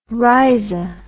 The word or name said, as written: rrhiza